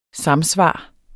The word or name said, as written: samsvar